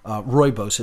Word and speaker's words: rooibos: Rooibos, it's spelled R-O-O-I-B-O-S, pronounced rooibos. Looks like Rue-ebos, but it's pronounced rooibos, and it's just, it's fantastic.